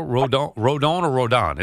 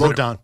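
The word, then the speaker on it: Rodón: Or how do you say it? Rodon? – Is Rodon. – Rodon. Yeah, that's what I thought.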